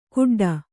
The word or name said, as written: ಗುಡ್ಡ